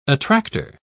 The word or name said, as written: attractor